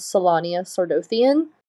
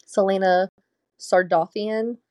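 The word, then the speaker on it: Celaena Sardothien: In my head, I was reading her name as Selania Sardothian. So that's kind of similar, but not quite correct. Selena Sardothian is the correct pronunciation, apparently, according to the pronunciation guide.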